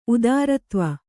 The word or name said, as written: ಉದಾರತ್ವ